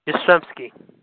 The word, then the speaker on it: Yastrzemski: You mean Yastrzemski. That would be Babe Ruth. – Babe Ruth. Okay? – Babe Ruth. – Pronounce that one name for me there, again. – Yastrzemski.